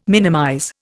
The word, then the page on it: minimize: Indian